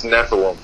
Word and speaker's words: Nephilim: And so the title's called, I'm not sure if I'm pronouncing this right, Nephilim, is that correct? – Yeah, it's Nephilim.